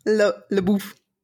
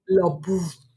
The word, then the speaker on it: LaBeouf: How do you say his name? – LaBeouf. – LaBeouf. – But it sounds like something you use in the shower to cleanse yourself.